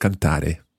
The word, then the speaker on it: canta: Let's hear the conjugation of cantare. – Canto, canti, canta, cantiamo, cantate, cantano. – Almost perfect. Well done. Just watch the stress of canto, canti, canta, the stress on the first syllable there.